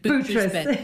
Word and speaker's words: Boutros Bear: But the Boutros, is that how I spell and pronounce it? – Boutros, Bear. Yeah.